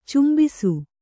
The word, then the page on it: ಚುಂಬಿಸು: cmbisu